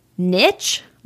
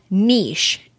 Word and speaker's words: niche: It is niche, like N-I-C-H, and niche, N-E-E-S-H.